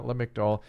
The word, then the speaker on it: Lamictal: And I would get down a lower dose of Lexapro first and then think about lowering that Lamictal. – Lamictal is how you say it? – Lamictal. Lamotrigine is the other name for it.